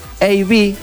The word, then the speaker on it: eBay: Se metió en una aplicación que se llama eBay, ok, así se pronuncia.